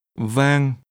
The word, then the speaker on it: vang: Vang. Rượu. Rượu. Rượu vang. Rượu vang. – This is pronounced with a low, falling, broken tone. – Rượu. – And a mid-level tone.